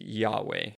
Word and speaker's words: Yahweh: But the Lord, in all caps, is the actual name of the God of the Bible of Israel, and it's Yahweh. That's how we pronounce it. It's YHWH or YHVH, depending on how you look at the Hebrew there, and we fill in the vowels within that.